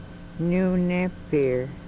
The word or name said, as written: Nunepir